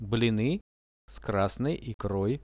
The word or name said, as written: bliny s krasnoi ikroi